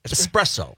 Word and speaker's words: espresso: China, this one was like espresso. Like, it's not ES. – Yeah, right. – S. You know, that was one of them. – But espresso? – It's espresso.